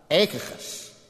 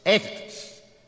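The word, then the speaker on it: Achaicus: Stephanus, Fortunatus, and then one of the hardest words in all of the New Testament to pronounce. It's got a deep guttural, and the CH in the beginning is silent. Echachus, Echachus.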